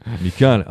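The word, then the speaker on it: Michał: Et donc, tu prononces assez mal son prénom. – Michal. Ah ouais, c'est Michal.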